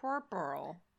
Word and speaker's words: puerperal: Puerperal. It is spelled P-U-E-R-P-E-R-A-L. So puerperal.